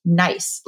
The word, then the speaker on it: Gneiss: There is a type of soil that we find frequently throughout Austria in the Wachau region, among others, and it's pronounced nice, like make it nice. It's spelled G-N-E-I-S-S. So if you did not know how it was pronounced, it looks like Gneiss, right?